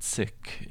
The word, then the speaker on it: Sikh: So this yogi comes to uh, the town where the Sikhs are, the Sikhs. To say it like a Westerner, but Sikh, it means a student or a student of the infinite.